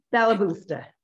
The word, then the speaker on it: balabusta: So your website and all about you is the Balabalusta, right? Did I say that right? – Balabusta. – Balabusta. I think I added an extra syllable there.